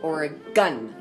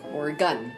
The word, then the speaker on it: Oregon: when we were there, we were told that it's pronounced Oregon, like Oregon.